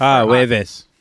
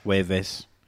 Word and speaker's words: Jueves: First day of the show for Jueves. – What? – Jueves.